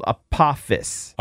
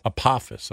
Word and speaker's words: Apophis: This guy says that I'm saying it wrong. It's called Apophis. – Apophis, okay. – Apophis. A-P-O-P-H-I-S.